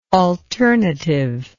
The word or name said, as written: alternativity